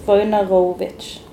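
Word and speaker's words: Wojnarowicz: Men jeg bare må lære meg å uttale navnet hans. Jeg har liksom bare lest det, men det står hvordan jeg skal uttale det. Her i begynnelsen, tror jeg. David Vojnarovic.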